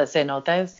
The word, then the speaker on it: cenotes: yes, the cenotes.